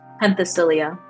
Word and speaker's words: Penthesilea: Penthesilea, I think is how you say her name? – Penthesilea. – Penthesilea. I was never going to get that.